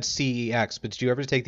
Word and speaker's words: CeX: Everyone pronounces it CEX, ooh. – But on their adverts they say sex. – No, yes. – No one calls it sex.